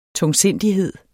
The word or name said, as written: tungsindighed